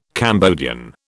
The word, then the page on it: Cambodian: British English